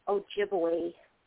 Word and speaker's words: Ojibwe: Oh, and it's pronounced Ojibwe. – Ojibwe. – Yes.